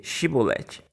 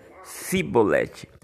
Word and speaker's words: Shibolete: Se o homem negasse, eles o mandavam dizer Shibolete. Se ele era de Efraim, dizia Sibolete, pois o povo de Efraim não consegue pronunciar essa palavra corretamente.